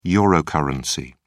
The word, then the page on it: eurocurrency: British English